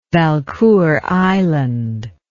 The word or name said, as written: Valcour Island